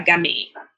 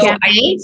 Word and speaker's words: Gamay: Right up there with a Pinot Noir, a Gamay. And that pairs really well with oxtail. – So Gamay? So spell that for us. – G-A-M-A-Y.